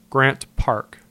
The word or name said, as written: Grant Park